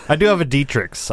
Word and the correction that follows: Dratini: It's Dratini.